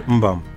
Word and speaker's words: Mbam: Still yet you can't pronounce my son. I'll pardon you for that. It's actually called Mbam. – M-B-A-M.